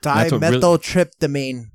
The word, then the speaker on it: Dimethyltryptamine: Dimethyltryptamine.